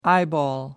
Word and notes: eyeball: American English

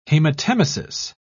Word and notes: haematemesis: British English

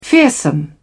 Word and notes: fearsome: British English

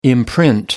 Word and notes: imprint: American English

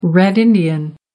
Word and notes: red-indian: American English

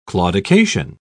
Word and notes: claudication: British English

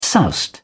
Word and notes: soused: British English

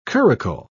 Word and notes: curricle: British English

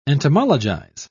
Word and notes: entomologize: British English

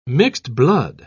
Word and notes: mixed-blood: British English

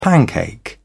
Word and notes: pancake: British English

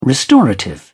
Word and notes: restorative: British English

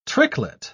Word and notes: tricklet: British English

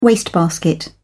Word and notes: wastebasket: British English

